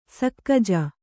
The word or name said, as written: ಸಕ್ಕಜ